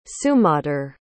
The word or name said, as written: sumadhur